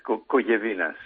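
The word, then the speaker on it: Kogevinas: En griego se pronuncia diferentemente, pero en español. – ¿Cómo se pronuncia? – Bueno, es Cogevinas.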